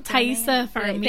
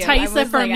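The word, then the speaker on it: Taissa Farmiga: Tasia Farmiga? – Oh, Thaisa Farmiga. – Thank you. – Thaisa Farmiga.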